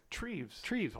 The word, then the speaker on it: Treves: But then you got to have that friction with Travis. Is it Travis? – Treves.